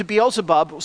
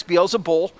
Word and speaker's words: Beelzebub: Well, then the Israelites took that term, and whenever anything was evil or rotten or awful, they would say that it was attributed to Beelzebub, sometimes Beelzebul. There were a couple of different variations on how to say his name.